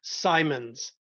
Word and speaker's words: Symonds: Symonds.